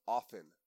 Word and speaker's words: often: And Dave Chappelle's dad said, it's pronounced often, not often.